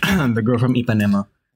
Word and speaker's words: Ipanema: That's right, The Girl from Ipanema, as it's also pronunciated sometimes. – Well, Ipanema is the correct pronunciation, I'm just saying.